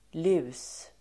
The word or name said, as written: lus